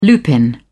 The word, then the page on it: lupin: British English